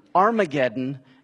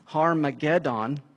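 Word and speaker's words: Harmagedon: Maybe you've heard the term Armageddon. It actually is pronounced Harmageddon.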